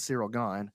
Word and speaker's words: Cyril Gane: Now, Cyril Gane, it's so you say I don't know who he is, but y'all don't even know how to pronounce his name. It's Cyril Gane.